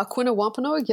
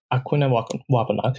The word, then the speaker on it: Aquinnah Wampanoag: is I hope I'm pronouncing this co- correctly, an Alkina Wampanoag? – Uh, Aquinnah Wampanoag, yes. – Aquinnah Wampanoag.